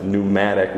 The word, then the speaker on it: pneumatic: And when the New Testament wants to say spirit, pneuma, it's really got a P on the front, pneuma, like pneumatic. We don't usually pronounce the P.